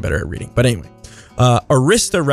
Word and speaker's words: Arista: There's Arista, which I believe in the past I was calling Aritzia on accident.